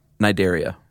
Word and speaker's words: Cnidaria: No, but they're in the phylum Cnidaria. – Cnidaria. – Why would they put the C there? – It's silent, man.